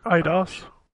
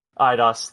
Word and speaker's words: Eidos: Eidos. – Eidos, thank you.